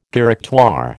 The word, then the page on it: Directoire: American English